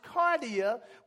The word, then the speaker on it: kardia: spelled K A R D I A, which is pronounced cardia, which is where we get our English word cardiac from.